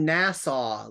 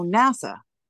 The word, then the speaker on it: NASA: It sounds like you're saying Nassau, like the county in New York. – No, NASA.